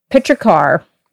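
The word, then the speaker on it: petrichor: Fiona and I are drinking, it's got a pronunciation key on the back. Pitchercar. It's the VQA.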